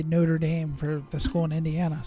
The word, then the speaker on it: Notre Dame: Which, by the way, I'm very proud of you for saying it right. – What? Notre Dame? – Yeah, not Notre Dame.